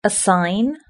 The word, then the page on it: a sign: British English